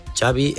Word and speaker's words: Xavi: Xavi Hernández. – Xavi. – Okay. Ya, yeah, Xavi like chocolate.